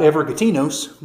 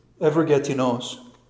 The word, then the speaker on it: Evergetinos: It's like an extant, and it has an accent on the last syllable. It's not Evergetinos, but it's Evergetinos.